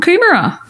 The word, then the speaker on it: Coomera: Cool morning at Coomera Park Run this morning, and a lovely picture of the group from Coomera. Is that how you pronounce it? – Coomera. Yes, absolutely.